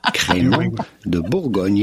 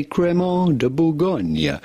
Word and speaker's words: Crémant de Bourgogne: I think we have the correct pronunciation queued up here for you. – Crémant de Bourgogne. It'd be fine to say Crémant de Bourgogne. Crémant de Bourgogne, French sparkling wine.